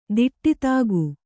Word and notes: ದಿಟ್ಟಿತಾಗು: Kannada

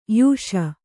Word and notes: ಯೋಷೆ: yōṣe

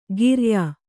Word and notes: ಕಿರಾಯ: kirāya

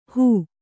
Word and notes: ಹೂ: hū

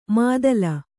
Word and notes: ಮಾದಲ: mādala

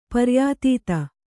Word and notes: ಪರ್ಯಾತೀತ: kannada

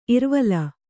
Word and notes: ಇರ್ವಲ: Kannada

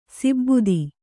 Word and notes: ಸಿಬ್ಬುದಿ: sibbudi

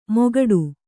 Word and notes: ಮೊಗಡು: kannada

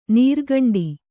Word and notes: ನೀರ್ಗುಂಡಿ: Kannada

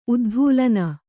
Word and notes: ಉದ್ಧೂಲನ: uddhūlana

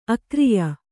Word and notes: ಅಗ್ರಿಯ: agriya

